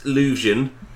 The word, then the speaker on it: Leuvsion: So it's pronounced Lusion, which is L-E-U-V-S-I-O-N.